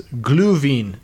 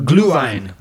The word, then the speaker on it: Glühwein: And then finally we have, I'll try to pronounce this, Glühwein. No, Glühwein.